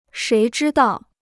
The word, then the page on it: 谁知道: shéi zhī dào